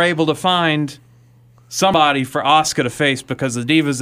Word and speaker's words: Asuka: Asuka, but yeah.